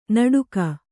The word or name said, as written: ನಡುಕ